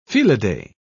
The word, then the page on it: felidae: British English